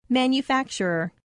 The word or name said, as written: manufacturer